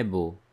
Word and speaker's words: beaux: And the word beau, it's spelled as B E A U X. However, it is pronounced beau. Here, the sound that we have practiced is O.